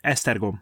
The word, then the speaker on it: Esztergom: Yeah, that's Esztergom.